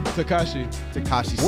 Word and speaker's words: Tekashi 6ix9ine: How do you say his name? – Tekashi 6ix9ine.